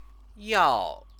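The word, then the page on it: 要: yào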